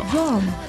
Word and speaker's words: Roam: Roam ROAM。